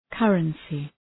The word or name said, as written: currency